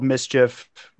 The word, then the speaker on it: MSCHF: So essentially, there's this art house called Mischief, not spelled like mischief, but it's like MSCHF or something like that.